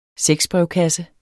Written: sexbrevkasse

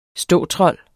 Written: ståtrold

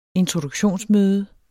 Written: introduktionsmøde

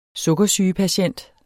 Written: sukkersygepatient

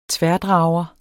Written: tværdrager

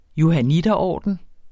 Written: johanniterorden

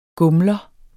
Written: gumler

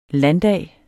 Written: landdag

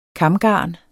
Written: KAMGARNET

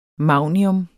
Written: magnium

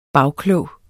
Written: bagklog

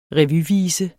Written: revyvise

